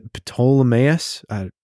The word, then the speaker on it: Ptolemais: They then go to Ptolemais. That's probably how you say that. – Yeah, the P is silent. Totally.